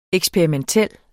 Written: EKSPERIMENTEL

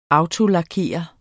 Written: autolakerer

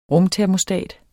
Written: rumtermostat

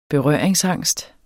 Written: berøringsangst

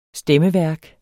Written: stemmeværk

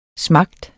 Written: smager

